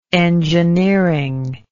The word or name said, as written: engineeringly